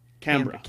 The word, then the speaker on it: Canberra: Canberra? How do you say it? – Canberra.